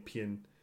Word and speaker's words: PIGN: I always call it Pien, because it's got like a soft J. – P-I-G-N and Conjurer, Kirsty's Metal Hands.